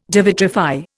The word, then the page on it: devitrify: Indian